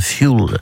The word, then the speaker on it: fuel: Donc déjà on prononce bien fuel parce que ça vient de l'anglais qui signifie combustible.